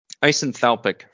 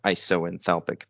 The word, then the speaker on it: isenthalpic: So isenthalpic first. This has to do with the enthalpy of the system. And an isenthalpic or isoenthalpic. Apparently both pronunciations are correct.